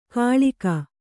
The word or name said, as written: ಖಳ್ಗಿ